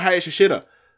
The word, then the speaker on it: Hayashishita: obviously a lot of the Japanese names, like, they have the way they pronounce and like how they're spelled. Like, it seems like when you pronounce them, like, you eat some, like, last vowels. So, like, her, you know, looking at it on paper, it's like, it's Hayashishita. Or sorry, Hayashishita. I'm so used to saying her name, like, the actual way you say it, just avoiding it.